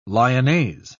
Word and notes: lyonnaise: British English

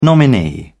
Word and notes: nominee: British English

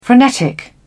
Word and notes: frenetic: British English

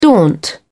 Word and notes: daunt: British English

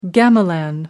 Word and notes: gamelan: American English

